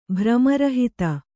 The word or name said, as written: ಭ್ರಮರಹಿತ